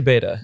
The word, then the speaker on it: beta: How do you pronounce it in America? In America, say beta.